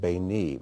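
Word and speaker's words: Beni (בני): And in between, you've got the word spelled Beit Nun Yud, which can be pronounced Beni, which means my son.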